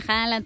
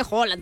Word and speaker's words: Haaland: De Haaland, porque bueno, ya también me dijeron que no se dice Haaland, pues que se dice Haaland.